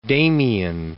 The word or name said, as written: Damien